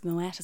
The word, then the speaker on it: Moët: It's pronounced Moët, as we know. The ultra wealthy would know it's pronounced Moët.